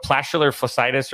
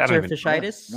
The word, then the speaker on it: plantar fasciitis: It's what, plantar fasciitis, or is that how you say it? – Plantar fasciitis. – No, I think you nailed it, dude.